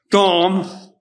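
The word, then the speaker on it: Dom (דם): But I looked at the Hebrew word for blood, and it's pronounced D-A-M, but it's Dom, and it's as in Adam.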